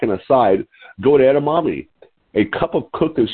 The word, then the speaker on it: edamame: How do you say that, Jimmy? Edamame? – Edamame. – Edamame. Okay. That's edamame.